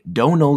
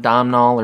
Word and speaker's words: Domhnall: Alejandro González Iñárritu is the director, co-stars Leonardo DiCaprio, and I had to look it up to make sure I was saying it correctly, Donal Gleason. – Donal? – Yeah. – I'm not saying that. – It's not Domhnall or Domhnall. From his mouth, it is not one of those like shitty translations.